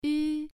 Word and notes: yu: 1

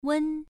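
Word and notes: wen: tone 1

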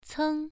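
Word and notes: ceng: tone 1

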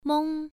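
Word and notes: meng: tone 1

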